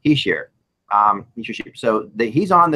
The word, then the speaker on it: Hischier: Now, what's interesting on this is although Hischier, I still can't say his name right, Hischier. – Right, you said it right that time.